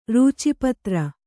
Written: ರೂಚಿಪತ್ರ

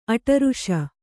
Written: ಅಡರಾಸು

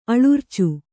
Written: ಅಲರಿಚು

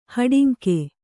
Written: ಹಾಡಂಗ

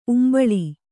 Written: ಉಂಬುಳಿ

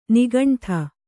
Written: ನಿಗಂಠ

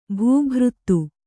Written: ಭೂಭೃತ್ತು

